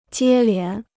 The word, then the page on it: 接连: jiē lián